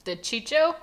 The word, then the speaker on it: DiCicco: I cannot pronounce his DiCicco? DiCicco? D-I-C-I-C-C-O. – DiCicco? – DiCicco, yeah. Obviously Italian-American.